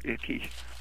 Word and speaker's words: Icke: Do you know who David Icke is, or Icky? It's spelled Icky.